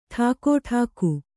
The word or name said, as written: ಠಾಕುಟೀಕು